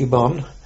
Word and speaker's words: Ubon: We were sent to Vietnam, French Indochina, to a place called Ubon, which is spelt Ubol, pronounce Ubon,